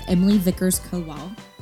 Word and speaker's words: Emily Vickers-Kowal: Well, we are sitting down with Emily Vickers-Kowal.